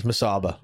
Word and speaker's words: Musaba: Every time, I'm putting an extra M in it, aren't I? I'm saying Musamba. – Yeah, it's Musaba.